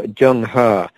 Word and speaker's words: Zheng He: the way his name is pronounced is Admiral Zheng He of China, set out to lead in the early 15th century, around like 1420 or so.